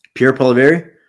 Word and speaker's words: Pierre Poilievre: Pierre Poilievre.